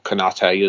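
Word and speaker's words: Konaté: And then we've got Konate there, or Konate, as some people are telling me you need to pronounce it.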